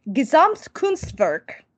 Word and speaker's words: Gesamtkunstwerk: where he described a vision of opera as Gesamtkunstwerk. I pronounced that one correctly because I've, I've tr- practiced it like 20 times because it's my new favorite word uh,